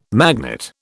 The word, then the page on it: magnet: British English